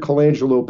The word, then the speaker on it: Colangelo: His name is Matt Colangelo, is I think how I pronounce his last name. It's C-O-L-A-N-G-E-L-O, Colangelo.